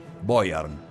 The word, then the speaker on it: Beuern: Boyer, B-E-U-E-R, B-E-U-E-R-N, Beuern, Boyern.